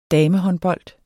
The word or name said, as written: damehåndbold